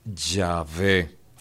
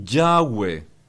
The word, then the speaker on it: Yahvé: pero que probablemente la mejor forma de pronunciar ese nombre en el hebreo es Yahvé. Los que son angloparlantes, los norteamericanos, británicos y todo el mundo que de habla inglesa, ellos van a decir Yahweh, pero Yahweh es por la pronunciación inglesa. Es un asunto más fonético que de ortografía, porque ellos en lugar de la V van a poner ahí la doble U.